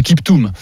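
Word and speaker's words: Kiptum: Et sachez qu'on prononce, hein, je me suis un peu trompé, Kiptoum.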